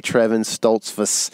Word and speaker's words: Trevon Stoltzfus: Trevon Stoltzfus. Did I say that right? – You said it right.